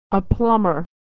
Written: a plumber

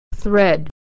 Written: thread

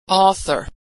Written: author

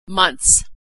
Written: months

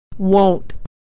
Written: wont

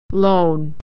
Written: lone

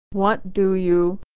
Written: what do you

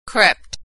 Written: crept

